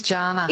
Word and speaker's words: Jana: It is Jana. – It is Jana. – Oh. Yes, I did grow up with Jana Vent on TV, but mine's a hard J, so thanks for checking.